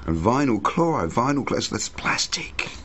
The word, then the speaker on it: vinyl chloride: Vinyl chloride. – Vinyl chloride.